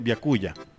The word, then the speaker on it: byakuya: ¿Cómo se pronunciaría en japonés? Byakuya. – Byakuya, así como suena.